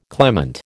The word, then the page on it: clement: American English